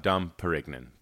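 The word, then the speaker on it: Dom Pérignon: That is pronounced Dom Perignon, by the way.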